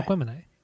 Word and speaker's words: Aquemini: All right, so my number three is Aquemini. That's how you pronounce it, right? – Yeah. – Aquemini.